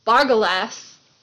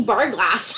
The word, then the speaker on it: Bargalas: Oh, I was thinking Barglass. oh. No, it's spelled like Legolas, but oh, like oh, Barglass.